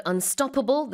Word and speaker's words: unstoppable: And with the word unstoppable, the stress is on the second syllable.